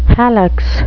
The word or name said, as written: hallux